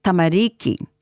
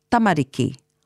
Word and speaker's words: tamariki: The elongated sound I mentioned tends to creep in at tamariki, making it become tamariki. But there's no long sound, just the same emphasis for all of the syllables. So, tamariki. Oranga tamariki. That's the correct pronunciation.